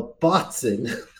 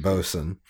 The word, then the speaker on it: bosun: Is that actually how you pronounce that? Because no, you actually pronounce it bosun. A bosun's mate, yeah.